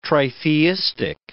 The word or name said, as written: tritheistic